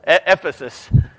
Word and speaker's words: Ephesus: It rhymes with Sisyphus. Ephesus.